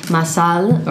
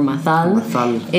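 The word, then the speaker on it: Mazal: So like Mazel Tov is M-A-Z-E-L, and Mazel means luck or lucky in Hebrew. – Mm-hmm. – But Masal or Mathal is the Sephardic pronunciation. So it's M-A-Z-A-L with an accent on the A.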